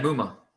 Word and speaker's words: Muma: How do you pronounce his name? – Chad Muma. – Muma. Okay. Yeah.